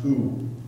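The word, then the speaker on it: OU (οὐ): It's OU, pronounced OU.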